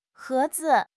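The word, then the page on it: he2 zi5: tone 2; neutral tone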